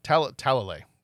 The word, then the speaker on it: Talalay: Talalay.